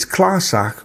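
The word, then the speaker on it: clàrsach: It's Klarsach with ch as in loch.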